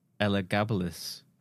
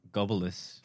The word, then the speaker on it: Elagabalus: So it's pronounced Elagabalus. – Goebbels. – Not Gobbleus.